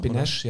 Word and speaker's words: Benes: Wobei ich sagen muss, dass Benes äh, oder Benesch, ich weiß gar nicht, wie spricht man den jetzt richtig aus, ähm, äh, finde ich von der Benesch, ne? Benesch, ja.